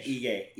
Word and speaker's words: Ige: This card Cater versus Calvin Cater versus Dan Ige. How do you say his name? Is it Ig or Ige? Ige. – Ige. Dan Ige.